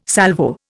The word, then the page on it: Salvo: Indian